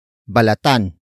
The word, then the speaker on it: balatan: Word for word, it's balatan mo ang patatas.